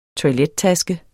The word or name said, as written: TOILETTASKER